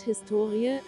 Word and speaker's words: Historie: Das Wort Historie, HS2IE, die Betonung liegt auf dem O, bezeichnet bis in das 18.